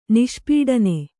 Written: ನಿಷ್ಪೀಡನ

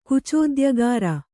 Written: ಕುಚೋದ್ಯಗಾರ

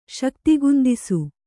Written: ಶಕ್ತಿಗುಂದಿಸು

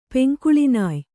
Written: ಪೆಂಕುಳಿನಾಯ್

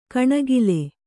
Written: ಕಣೆಕಾಲ್

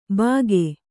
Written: ಭಾಗೆ

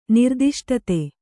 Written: ನಿರ್ದಿಷ್ಟತೆ

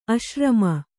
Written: ಆಶ್ರಮಿ

